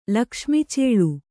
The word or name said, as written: ಲಕ್ಷ್ಮಿಚೇಳು